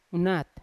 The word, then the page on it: unat: Yawa